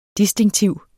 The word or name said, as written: distinktiv